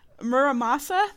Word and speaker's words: Muramasa: It's called, Muramasa, the Demon Blade.